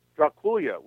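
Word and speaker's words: Drăculea: But he heard stories about Dracula, the real historical Dracula, and he heard the name, and the name sounded like Dracula. Draculia was what he heard, which is the Romanian pronunciation of the name. Dracul, meaning dragon, and Ya, meaning son of.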